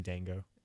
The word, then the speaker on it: Django: I say Django, like the D is silent. – I know the D is silent. It's a joke in the movie. – It's actually Django.